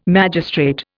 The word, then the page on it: magistrate: Indian